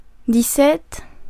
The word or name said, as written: dix-sept